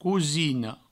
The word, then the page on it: cosina: cousino